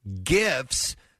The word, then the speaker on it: GIF: Jif said with a soft G is America's number one peanut butter. Gifs said with a hard G are the frequently shared looping videos that add humor, culture, and entertainment into people's daily conversations.